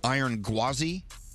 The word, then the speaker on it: Iron Gwazi: You can take on the Iron Gwazi, the fastest, steepest hybrid coaster in the world.